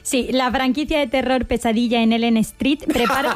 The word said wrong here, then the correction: Elm Street: Que no se dice así. – ¿Y cómo se dice? – Elm Street. – Ah, bueno, es que yo lo he españolizado, Elm Street. – Es que se escribe igual, ¿eh?